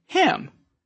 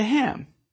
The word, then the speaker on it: hymn: How about the silent N in hymn? Where can you find a hymn? In a hymnal.